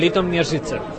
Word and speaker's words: Litoměřice: No, vivo en Litoměřice. Después de mucho practicar la re, ri, ro, ru, ru, sí, ahora puedo pronunciarla.